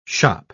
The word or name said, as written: shoppe